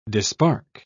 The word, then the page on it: dispark: British English